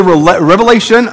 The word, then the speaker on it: Revelation: It's the book of Revelation, not Revelations. There is no S.